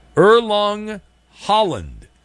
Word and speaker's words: Erling Haaland: A Norwegian soccer star, Erling Haaland, I believe is his name. I butchered his name last night, but I believe it's Erling Haaland.